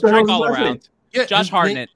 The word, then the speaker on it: Hartnett: It's pronounced Hartnett.